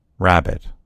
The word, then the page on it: rabbit: American English